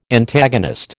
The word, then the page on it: Antagonist: American English